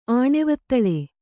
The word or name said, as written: ಆಣೆವತ್ತಳೆ